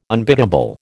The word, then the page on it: unbiddable: American English